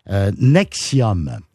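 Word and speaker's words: NXIVM: De Keith Raniere, 60 ans, fondateur de la secte Nexium. Mais ça s'écrit avec un V au lieu d'un U.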